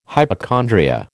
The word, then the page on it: hypochondria: American English